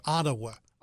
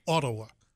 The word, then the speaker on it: Ottawa: And everybody who says Ottawa. – Ottawa. – It's not. It's Ottawa. That's how they say it.